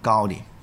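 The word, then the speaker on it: Gaudí: Gaudi 呢个系加泰隆尼亚文读音。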